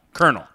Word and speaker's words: colonel: It should be spelled K-E-R-N-A-L, colonel. – Well, colonel, like a popcorn kernel is spelled K-E-R-N-E-L, I believe.